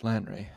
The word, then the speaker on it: Lanre: Oh, it ends with a long A sound, as in fable. So A is magic, and A is Lan. Lanray. Okay, that's spelled A-L-A-N-R-E. Lan. Lanray. Lanre. That's awkward. It's just, I feel it should be Lanre. It's Lanre. Okay.